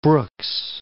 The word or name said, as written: Brooks Range